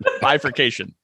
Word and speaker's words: bifurcation: Bifurcation. – Too many syllables in there, pal. – Well, when you get mad, you get in there. – You just throw an extra syllable in there.